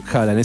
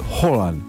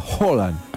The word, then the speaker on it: Haaland: Ah, escuché de noruegos decir que no es Haaland, es Haaland. Con O. Se produce una O tipo aspirada, Haaland.